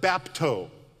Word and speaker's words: bapto: In other words, there's the word bapto. B-A-P-T-O is how we would spell it in English, the Greek word bapto, which literally means just to dip.